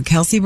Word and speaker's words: Kelce: And that was one of the, I'm going to get his last name wrong, the Kelce brothers.